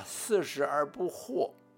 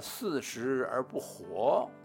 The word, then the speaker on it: 四十而不惑: 因为那天吴主席呀，把“ 四十而不惑” 说成了“ 四十而不活”。那天我问爸爸，那个字到底该怎么念呢？